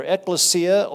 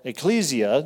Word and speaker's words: ekklesia: The Greek word for assembly, by the way, is either ekklesia or ecclesia, depending on how you wish to pronounce it, and that gives us the English title of the book, Ecclesiastes.